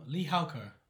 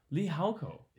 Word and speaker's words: Lijauco: Is that your preferred pronunciation? – Um, Lee Halko. Lee Halko.